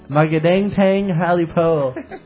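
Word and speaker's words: Magandang tanghali po: How about this one? Magadang tang holly po. That's how you say it. Magadang tang holly po.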